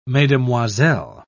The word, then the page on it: mesdemoiselles: British English